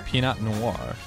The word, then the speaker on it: Pinot Noir: Pinot Noir.